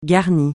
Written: garni

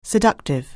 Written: seductive

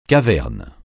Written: caverne

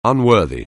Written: unworthy

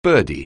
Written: birdie

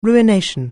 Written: ruination

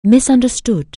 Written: misunderstood

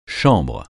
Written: chambre

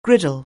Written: griddle

